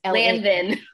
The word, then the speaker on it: Lanvin: The Lavon? How do I say it? – Yeah, Lavon. – Lavon? – Lavon. – It's Lanvin.